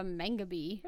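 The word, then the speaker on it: mangabey: Okay, I've also never heard of a mangabee. – Really? – I'm assuming I'm saying that right. – Yeah, that's how you say it.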